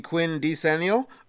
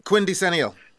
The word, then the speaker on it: quindecennial: You mean you're doing something for the Quinn decennial? – Yes. I don't even try to say it again. How do you pronounce it? – Quindecennial.